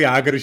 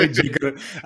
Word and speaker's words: Jágr: jako Jágr, že jo, který ho tam ty komentátoři vyslovovali Jigger, a, ale tohle vlastně nejde vyslovit špatně.